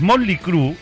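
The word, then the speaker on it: Mötley Crüe: Y hay una película que es digna de ver en Netflix y estamos hablando, ya ves cómo lo voy a decir bien, los Motley Crew y su Wild Side. ¿Seguro que se dice así? Seguro. Motley Crew Wild Side.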